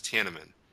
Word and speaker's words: Tiananmen: And then Scott later refers to it and says it right and says Tiananmen.